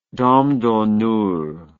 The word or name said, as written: dame d'honneur